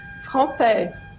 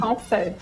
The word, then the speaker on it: Francé: Como é que pronuncia seu sobrenome, doutora? – Francé. – Francé? Assim? Olha aí. – Francé.